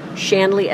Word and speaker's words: Shanley: Oh, Suzanne Shanley from the Agape community. But the Paulus Center is here. – What? How do you spell that name? – What? Shanley, S-H-A-N-L-E-Y. – And Suzanne is S-U-Z-A-N-N-E.